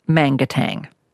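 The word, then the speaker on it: Manangatang: Now, it looks like your suburb would be pronounced Mangatang. – Correct.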